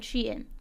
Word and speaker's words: Shein: I found a pronouncer, it said Shein. So that's what we're going with for tonight.